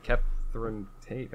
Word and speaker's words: Catherine Tate: the other one, Catherine Tate.